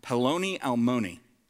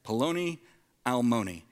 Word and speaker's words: Peloni Almoni: In Hebrew, it's pronounced Peloni Almoni, okay? Peloni Almoni, which, does anybody happen to know what that means?